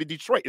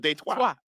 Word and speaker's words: Detroit: You've been saying it right, because in Detroit, we don't pronounce them the French. We don't use the French pronunciations. That's why we call the city Detroit instead of Detroit.